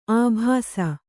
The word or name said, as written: ಅಬ್ಬೆಸ